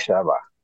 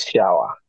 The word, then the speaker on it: Shaba: And you said it correctly, Tendai Shaba. But in the north of Malawi, B-A is pronounced as wa, so it's shower.